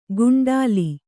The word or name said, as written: ಕುಂಟಲಿ